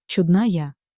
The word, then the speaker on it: чудная: Во-вторых, кое-где в тексте вы встретите слова с проставленным ударением, например, «чудная». Это не опечатка и не прихоть. Ударение здесь стоит нарочно, чтобы передать особую интонацию, легкое удивление, тепло, а порой и добрую насмешку, с которой это слово произносится. То, что при обычном ударении «чудная» попросту потерялось бы.